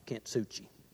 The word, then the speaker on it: Kintsugi: Now, if I said that wrong and you're from Japan, I apologize. But that's the best that a fellow from Mississippi can pronounce, Kintsugi. Dr. Sweeney has approved it.